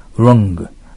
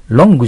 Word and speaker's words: wrong: Wrong. Long じゃないよ。 L の発音じゃないよ。 Wrong よ。この発音気をつけて。 L の long って長い。 Wrong というのは、うの口をして顎を引かんといけん。